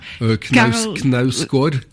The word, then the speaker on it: Knausgård: Knausgård.